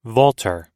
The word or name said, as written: vaulter